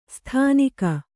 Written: ಸ್ಥಾನಿಕ

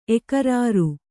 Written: ಎಕರಾರು

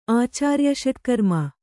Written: ಆಚಾರ್ಯಷಟ್ಕರ್ಮ

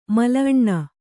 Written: ಮಲಅಣ್ಣ

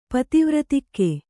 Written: ಪತಿವ್ರತಿಕ್ಕೆ

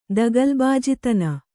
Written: ದಗಲಬಾಜಿತನ

